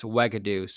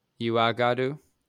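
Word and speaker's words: Uagadou: I've been reading just casually about some of these schools, and the Ugandan one is pronounced Wagadoo, so my bad on that one. – Mm. What'd you say the first time? – Uagadoo, because it's U-A-G-A. – Fucking idiot. – D-O-U.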